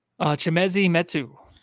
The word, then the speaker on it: Chimezie Metu: Chimezie Metu.